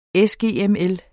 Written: sgml

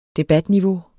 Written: debatniveau

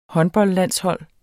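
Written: HÅNDBOLDLANDSHOLD

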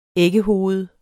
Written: æggehoved

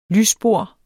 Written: lysbord

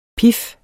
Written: pif

